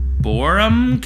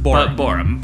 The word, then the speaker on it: Boreham: Boreum Cavern. – Correct. – Spelled B-O-E-R-O-U-M. – B-O-R-E-H-A-M. – Okay. – Boreham, but pronounced Boreum. – But Boreum. Yeah.